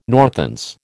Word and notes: Northants: American English

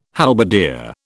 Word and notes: halberdier: British English

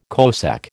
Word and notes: cosec: American English

